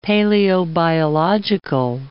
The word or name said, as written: paleobiological